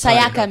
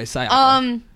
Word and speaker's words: Sayaka: Emphasis on the wrong word. – It's Sayaka. – Okay, Sayaka.